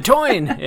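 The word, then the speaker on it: Táin: I think the toine is a Irish folklore / mythology book that I had to read in college, but it's spelled T-A-I-N, but it's pronounced the toine.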